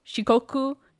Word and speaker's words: Shikoku: Awaji, Shikoku, Ikikyushu, Tsushima, and Honshu, which is the biggest island.